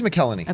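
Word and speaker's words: McElhenney: This, I always mispronounce his last name. McKelleny? – McKelleny? – It is McKelleny.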